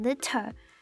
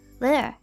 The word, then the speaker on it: litter: 那还有一个字的子音可能会需要我们注意一下，那就是 litter 这个字。那这个字还蛮特别的，影片里的讲者是英国人，那英国腔念这个字的话就会把那个 TT 发的比较清楚，所以他念的就是 litter。不过对于习惯美式发音的人来讲，这里的 double T 我们要把它念的比较不清楚一点，所以我们就不会念成 litter，而是会念成 litter。